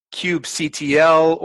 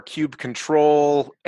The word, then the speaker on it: kubectl: Well, because there's a tool that you use that's kubectl or kubectl, and that's kind of a mini debate about how you pronounce something.